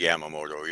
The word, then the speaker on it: Yamamoto: But so you were saying Yamamoto. Am I saying that right? – Yes, Yamamoto, Yoshinobu.